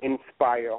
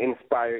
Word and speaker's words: Nspire: I can be found on Facebook at Inspire. And I do want to just let everyone know that Inspire is spelled with the letter N, N-S-P-I-R-E, and not I-N, just the letter N, Inspire.